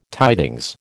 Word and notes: tidings: American English

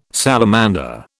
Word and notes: salamander: British English

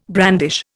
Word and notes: brandish: Indian

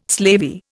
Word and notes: slavey: Indian

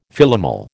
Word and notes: Philomel: American English

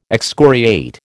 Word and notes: excoriate: American English

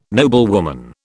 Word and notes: noblewoman: British English

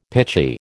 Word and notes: pitchy: American English

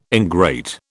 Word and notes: ingrate: British English

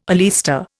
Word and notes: oleaster: Indian

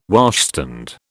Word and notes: washstand: British English

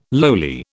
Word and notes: lowly: British English

